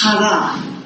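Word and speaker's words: hara (χαρά): It's hara. It's a guttural. But I don't have the guttural because I don't have much throat anymore after the accident. But it's C-H-A-R-A. Other people pronounce it chara. But I have the benefit of learning from a Greek. So hara sounds so much better. And the root word, ha, which is C-H-A-R or X-A-R in the Greek lettering, is a root word for these other words.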